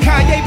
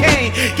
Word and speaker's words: Kanye: All I need is y'all to pronounce my name. It's Kanye, but some of my plaques, they still say Kane.